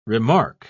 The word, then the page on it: remarque: British English